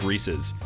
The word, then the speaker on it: Reese's: Like, one of the things that right on their landing page, it says, by the way, it's pronounced Reese's. Rhymes with pieces. – Yeah. – Not feces.